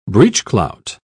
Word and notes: breechclout: British English

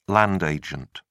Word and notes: land agent: British English

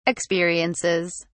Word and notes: experiences: British English

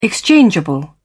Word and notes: exchangeable: British English

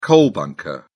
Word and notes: coal bunker: British English

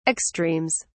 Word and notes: extremes: British English

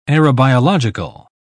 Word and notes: aerobiological: British English

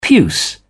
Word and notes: puce: British English

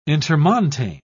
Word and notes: intermontane: British English

